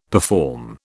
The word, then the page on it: perform: British English